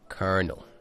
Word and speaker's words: colonel: Also, kernel is spelled weird. Colonel. – Oh, really? I wrote kernel like popcorn kernel. – No, colonel.